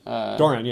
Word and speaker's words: Doran: I think Doran, am I saying it right? – Doran, yeah.